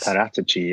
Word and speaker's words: Paratici: Paratici, yeah.